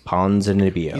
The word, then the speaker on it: Ponzinibbio: Ponzinibbio.